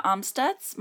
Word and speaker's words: Amstutz: You can find all of my social media at Sandra Amstutz.